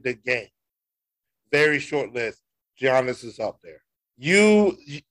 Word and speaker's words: Giannis: If he's in the top 10, say his name right. It's Giannis. It's not Giannis. It's Giannis.